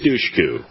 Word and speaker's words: Dushku: Eliza Dushku. Am I saying it right? – Eliza yes. Dushku.